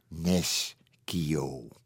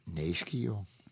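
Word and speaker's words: Nescio: Nes Kio. dat is niet goed. Ik hou me dus aan de grammatica. En zeg Neschio, Neschio.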